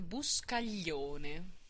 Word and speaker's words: Buscaglione: Fred Buscaglione.